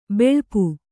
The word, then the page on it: ಬಳಪ: baḷapa